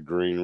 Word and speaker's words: Green River: Because me and actually, there's a river down here, the Green River, and the proper way to pronounce it is green, but I mispronounce it on purpose just to piss my old lady off all the time.